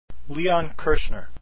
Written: Kirchner

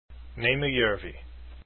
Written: Jarvi

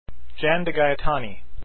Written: DeGaetani

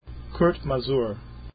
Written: Masur

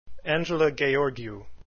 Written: Gheorghiu

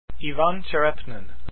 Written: Tcherepnin

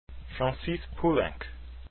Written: poulenc

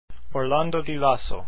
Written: Lasso

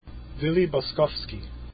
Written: Boskovsky

